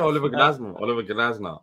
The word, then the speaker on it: Glasner: Am I pronouncing that right? – Glasner? Yeah, Oliver Glasner.